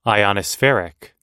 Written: ionospheric

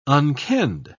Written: unkenned

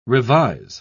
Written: revise

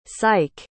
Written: sike

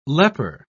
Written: leper

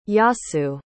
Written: Yasu

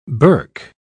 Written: berk

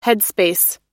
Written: headspace

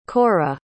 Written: cora

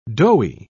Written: doughy

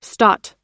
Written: stot

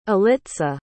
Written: alitza

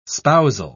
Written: spousal

